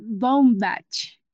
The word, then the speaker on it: Baumbach: Que también estuvo junto a su esposo que se llama Noah Bucham, ah, si lo pronuncio bien, ¿verdad? Ah, Baumbach,